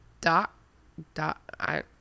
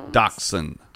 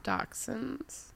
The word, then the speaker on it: Dachshund: What's the long name for wiener dogs? – Dock hounds? – Dachshund. – Dachshunds. – But it's spelled D-A-C-H-S-H-U-N-D.